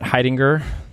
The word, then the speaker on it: Heidinger: It's Matt Heidinger. I like it's the word hiding with a gur at the end.